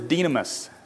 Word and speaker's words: dynamis: So the Greek word is dynamis. So it's spelled D-Y-N-A-M-I-S. So think dynamite, okay?